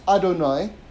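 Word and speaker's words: Adonai (אדני): But you've got another name, which is also, by the way, pronounced Adonai. Okay? But it's spelled this way. Aleph Dalet Nun Yud.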